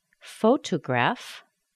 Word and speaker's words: photograph: But now listen. Photograph. Photograph. What happened? Photographer. We've got the primary stress in the second syllable. But photograph. We've got the primary stress on the first syllable. The second syllable is weak. So that T in the beginning of the weak syllable sounds like a D.